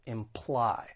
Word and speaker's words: imply: So when it comes to pronouncing this verb, it has two syllables. The stress is on the second syllable, imply.